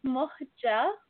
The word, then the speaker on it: Muhga: It's Muhja Altajani. So both of the G's are pronounced like a J.